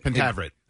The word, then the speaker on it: Pentaveret: All right, you said Pentavlet, but it's spelled Pentaveret? – Pentaveret. Well, okay. Yes, I said Pentavlet. I added an L by accident.